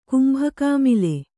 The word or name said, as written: ಕುಂಭಕಾಮಿಲೆ